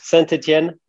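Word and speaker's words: Saint-Étienne: Saint Etienne, is that correct? – Yeah, close. Saint Etienne is correct, yes.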